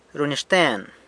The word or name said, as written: runyaxten